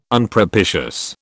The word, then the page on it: Unpropitious: British English